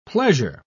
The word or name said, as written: pleasure